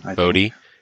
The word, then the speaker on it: Bote: But yeah, I mean, I'm looking, it looks like it's like a Kipnis, David Bote? How do you pronounce his name? I don't even know. – Bote.